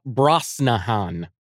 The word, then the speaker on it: Brosnahan: and Rachel Rosnahan. – Uh, Bra- Brosnahan. But yes, Brosnahan.